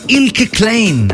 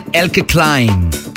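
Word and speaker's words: Eelke Kleijn: Y te estoy hablando de este mega productor llamado Ilke Klein, así se pronuncia su nombre. En su idioma nativo, él es de los Países Bajos, aunque en inglés dicen Elke Klein. Se pronuncie como se pronuncie, yo prefiero decir Ilke Klein.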